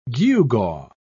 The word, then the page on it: gewgaw: British English